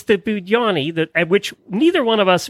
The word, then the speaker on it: Budenny: So tell me how you say the name of this breed. – You kind of pronounce it Budenny. – Yay! Budenny. I've been correct, actually. I've been doing it right.